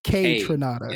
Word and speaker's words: Kaytranada: Kate Trinata. – Kate, like K, Tronada. Yeah, K Tronada. Yeah, there you go.